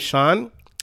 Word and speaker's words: Sean: For example, my name's Sean, spelled with S-E-A-N, but it's a pretty common name. I think people would agree that if you see S-E-A-N, that name is Sean.